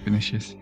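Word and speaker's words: Vinícius: Vinicius. – You have to say it correctly to get the point. – V-I-N-I-C.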